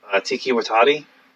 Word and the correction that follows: Taika Waititi: Taika Waititi.